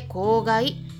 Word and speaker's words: 香害: とで、えー、今日ねイベントがあるんですよえな何ですかって言ってるそこのあなたあれですよあれはい何でしたでしょうか公害ですね香りの害という漢字 2 つを組み合わせて公害って読む